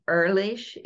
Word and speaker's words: Ehrlich: It's actually Ehrlich. It rhymes with girlish.